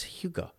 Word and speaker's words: hygge: Jogging pants, hygge? Takeout. It looks like it'd be pronounced higgy, but it's hygge. – Hygge.